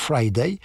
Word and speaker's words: Fridayy: Il a trouvé une très belle solution pour qu'on puisse le retrouver lorsqu'on veut avoir des infos sur Friday. Il a rajouté un Y, donc ça s'écrit F-R-I-D-A-Y-Y. Donc en principe, il faut prononcer Friday. Ben oui, il me semble que c'est un Y, Friday. Deux Y, Friday.